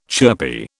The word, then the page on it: chirpy: British English